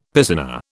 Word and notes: piscina: British English

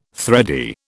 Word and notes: thready: British English

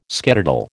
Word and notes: skedaddle: American English

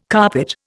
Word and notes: carpet: Indian

